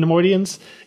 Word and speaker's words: Neimoidians: I do like just all the alien creatures we're we're uh, introduced to, like just th- these incredibly cowardly Neimoidians. Is that how you pronounce it? – Yeah, Neimoidians. Yep. Yeah, Neimoidians.